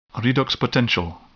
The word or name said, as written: redox potential